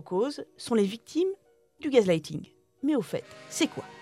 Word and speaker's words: gaslighting: Alors en, en, anglais, on dit gaslighting comme quelqu'un, euh, comme un, homme qui me mansplainait mon, livre, me, l'a fait remarquer récemment.